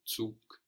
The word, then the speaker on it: Zug: Here the G on the end of the word is hardened, so it sounds like a K, Zug.